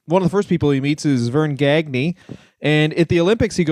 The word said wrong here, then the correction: Gagne: I think you mispronounced that. It's actually pronounced Gagner.